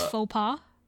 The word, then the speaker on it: faux pas: I think it's faux pas. – Faux pas? Yeah. Okay, there we go.